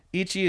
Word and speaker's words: Ecchi: Whereas Ichi, I'm probably spelling that wrong. – It's spelled E-C-C-H-I. Yep. – Ichi